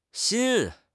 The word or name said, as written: xin1